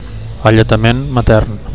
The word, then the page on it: alletament matern: Catalan